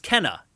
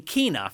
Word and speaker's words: Kena: Like, that's not how the dev team pronounces it, Kena, apparently. – Yeah, we actually had it right at the beginning. It's Kena. And then in the trailer, they were saying Kena for some reason.